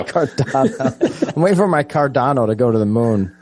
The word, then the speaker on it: Cardano: How do you say it? – Cardano. – Cardano. My Cardano.